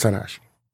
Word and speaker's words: Tanahashi: He won the match at Wrestle Kingdom 14 over Tanahashi. Did I say that right? Yeah. – Yes, it was Tanahashi.